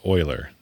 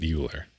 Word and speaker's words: Euler: Though I would say it's pronounced Euler, not Euler.